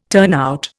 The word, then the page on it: turnout: Indian